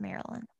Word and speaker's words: Mary Lynn: Spelled Mary Lynn, but pronounced Maryland.